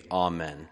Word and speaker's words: Amen: And so the A is actually, it's not a long A, right? It's a short A. Amen.